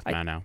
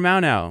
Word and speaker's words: Mauno: You can't go wrong with Manow.